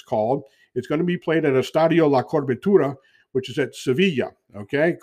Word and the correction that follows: La Cartuja: Cartuja, I should say. Excuse me, I want to pronounce that right. Estadio La Cartuja, which is in Sevilla.